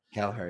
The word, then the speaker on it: Kelleher: Although there was no Alisson, and I don't know how to pronounce his name, but Kelleher. – Kelleher, yeah.